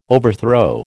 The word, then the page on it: overthrow: American English